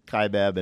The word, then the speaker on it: Kaibab: We're doing with the K-Bot. How do you say that again? I said it wrong. – He's going down south, Kaibab, and up Bright Angel.